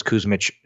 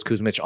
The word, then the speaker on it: Kusmich: And again, all my information is Nicholas Kuzmich, you know, slash Nicholas Kuzmich on any social channel as well. – And that's spelled K-U-S-M-I-C-H.